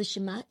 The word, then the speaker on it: Shema: Well, it's called the Shema Israel.